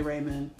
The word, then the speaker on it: ramen: I pronounced it ramen. But I did it when I was younger. I did say ramen. Nobody corrected me until I started just hearing ramen. And I was like, oh, I was saying it wrong.